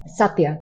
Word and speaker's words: Satya: Satya. – Satya.